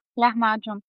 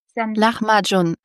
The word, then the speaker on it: Lahmacun: Also genau, als wäre da so ein CH, also wie beim Lachen sozusagen, ein Lahmacun. – Lahmacun.